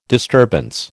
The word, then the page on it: disturbance: American English